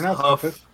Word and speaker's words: Hough: It's pronounced Huff. It's pronounced Huff.